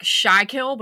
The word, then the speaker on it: Schuylkill: It's spelled like Schuylkill, but it's actually Schuylkill is how you pronounce it.